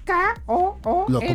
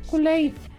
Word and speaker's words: Kool-Aid: Se pronuncia la palabra Kool-Aid? – K-O-O-L-A-I-D. – ¿Cómo se pronuncia? – Kool-Aid.